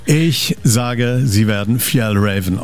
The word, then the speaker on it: Fjällräven: Fjällräven.